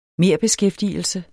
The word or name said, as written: merbeskæftigelse